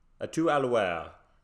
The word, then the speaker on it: À tout à l'heure: À tout à l'heure.